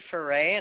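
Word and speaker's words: Foret: Debbie Foray, and the last name is spelled F as in Frank, O-R-E-T.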